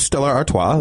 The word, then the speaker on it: Stella Artois: It's Stella Artois. That's how you say it.